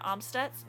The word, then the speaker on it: Amstutz: You can talk to me on Twitter or any other social platform at Sandra Amstutz.